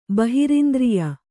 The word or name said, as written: ಬಹಿರಿಂದ್ರಿಯ